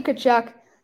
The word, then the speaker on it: Tkachuk: Matthew Tkachuk.